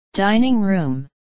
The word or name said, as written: dining room